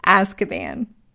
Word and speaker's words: Azkaban: I always say that wrong. Azkaban.